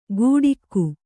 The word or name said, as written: ಗೂಡಿಕ್ಕು